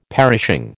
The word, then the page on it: perishing: American English